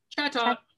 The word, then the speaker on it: Chatot: Chatot? – Chatot.